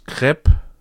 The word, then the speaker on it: Krepp: Crepe, ja, K-R-E-P-P. Aber hier steht halt Crepe wie der Crepe.